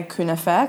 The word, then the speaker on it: kunafa: You're not going to enjoy kunafa anywhere else. – We're going to do a little exercise. Explain. So I'm going to say kunafa because that's how they say it in Turkey. Kunafa in Arabic.